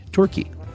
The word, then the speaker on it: Torquay: You mentioned the other week, and I think the Agatha Christie segment, we would pronounce it tour, as in tour bus, in key, Torquay.